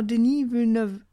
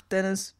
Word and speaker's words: Denis Villeneuve: man könnte ihn auch Denis Villeneuve, also ganz französisch aussprechen. Mhm. – Ich nenne ihn einfach Dennis, weil, mein Gott, ist mir jetzt egal.